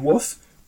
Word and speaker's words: WWOOF: I first worked on permaculture farms through the organization WOOF, which is spelled WWOOF, which is Worldwide Opportunities on Organic Farms.